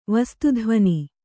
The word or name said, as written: ವಸ್ತುಧ್ವನಿ